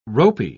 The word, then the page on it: ropey: British English